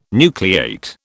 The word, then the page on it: nucleate: British English